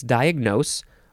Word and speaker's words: diagnose: It's diagnose, or diagnose, or diagnose. You got to emphasize the last syllable.